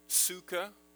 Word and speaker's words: sukkah: Pronounced in Hebrew, sukkah, like we say, that's the sing- singular form.